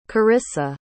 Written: corrissa

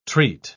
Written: treat

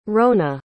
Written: rona

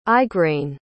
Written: Igraine

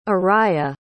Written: ariah

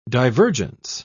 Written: divergence

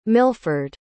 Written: milford

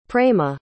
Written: prema

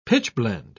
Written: pitchblende

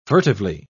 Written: furtively

